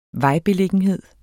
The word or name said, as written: vejbeliggenhed